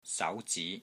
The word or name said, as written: SAU2 ZI2